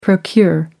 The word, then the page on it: procure: American English